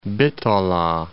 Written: Bitola

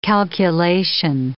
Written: calculation

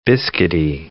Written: biscuity

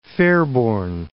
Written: Fairborn

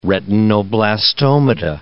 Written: retinoblastomata

